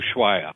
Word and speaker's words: Ushuaia: So how did this Columbia Ushia, how do you pronounce the other word? Ushia? – The other country. – Ushuaia. – Ushuaia.